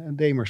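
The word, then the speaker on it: Dahmer: Die, ik wilde eigenlijk over die hele Damer, die Jeffrey, zeg, spreek het goed uit? Ja, Damer spreek ik uit, spreek je het uit.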